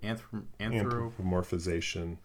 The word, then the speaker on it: anthropomorphization: And the fact that there's so much anthropomorphic anthropomorphization. Did I say that right? – Anthropomorphization.